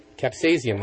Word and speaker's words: capsaicin: Capsaicin. Thank you. Capsaicin, the compound in chili peppers that makes them hot, may speed up your metabolism. According to a study in Physiology and Behavior, researchers say capsaicin activates your sympathetic nervous system in that the effects on metabolism can last for more than 4 hours after eating.